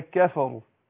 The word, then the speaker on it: كفروا: فما ينفعش اقول والذين كفروا، كفروا دخلنا كده على الايه، على القاف، لكن اقول والذين كفروا ك مش قا. والذين كفى الفاء مرققه، يعني لو واحد نطق الكاف قاف نطقها غلط، هيترتب على الخطا ده خطا تاني، هيروح مفخم الايه الفاء، يقول كفروا كفى لا كفى، يبقى الفاء هنا برضو ايه مرققه، او الكفروا رو، الراء هنا برضو مفخمة، يبقى الراء هنا عشان مضمومة تفخم.